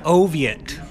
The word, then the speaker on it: Oviatt: My name is Sean Oviatt. – Sean Oviatt. – Yes. – Now, how do you say your last name? – Oviatt. – Spell that. – O-V-I-A-T-T.